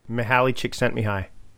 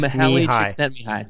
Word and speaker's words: Mihaly Csikszentmihalyi: Flow I talk about throughout the book, and I try to pronounce the guy's name who coined the term, but I'm Mihaly Csikszentmihalyi. – Yes, that one. – Just say Csikszentmihalyi. That's it. – Csikszentmihalyi. Mihaly Csikszentmihalyi.